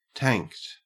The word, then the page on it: tanked: Australian English